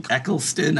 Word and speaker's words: Eccleston: I've seen, so I saw the Eccleston. I'm saying his name wrong. – No, that's right.